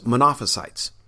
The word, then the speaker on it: Monophysites: Because they so emphasized his deity at the cost of his humanity, they were branded as Monophysites, or sometimes you'll hear it pronounced as Monophysites.